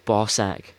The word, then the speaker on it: BAWSAQ: It's called Bossack, which is pretty funny. It's spelled B-A-W-S-A-Q.